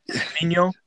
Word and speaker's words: Vigneault: Did I say his name right? Is that Vigneault? Yeah, I think you got his last name right.